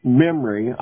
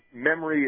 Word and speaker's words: MEMRI: They refer back to this article in Memory, I guess is how you pronounce it, M-E-M-R-I.